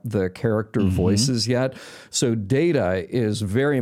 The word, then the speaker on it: Data: It's specified to pronounce it as Data.